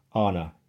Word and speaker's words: Auna: And uh, uh her name is spelled Holly, as you would expect, and then A U N A, but pronounced Anna, A H N A, right?